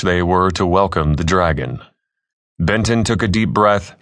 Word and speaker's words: DRAG.N: The UHI's disbursement of regular antibodies for a greener nation, DRAG.N for short, pronounced Dragon, had been the president's platform for re-election.